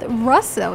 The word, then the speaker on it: Russo: Right here with us is Seth Russo. Did I say your name correctly? – That's fine, Russo. – Russo.